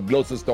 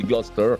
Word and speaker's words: Gloucester: Alors Leinster, bien sûr, on dit Leinster. On prononce pas comme Gloucester, on dit Gloucester.